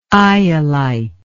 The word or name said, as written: iolite